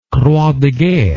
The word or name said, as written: croix de guerre